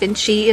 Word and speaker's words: VNCCII: It's spelled V-N-C-C-I-I, but it's pronounced Vinci, inspired by the creative polymath Leonardo da Vinci.